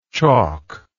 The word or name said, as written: chalklike